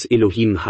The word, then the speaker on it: Elohim: So würde es Elohim heißen, so geschrieben und so ausgesprochen.